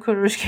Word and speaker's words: Kurosh: It is Kurosh, so we don't need to roll the R or anything else. It's perfect as you said it.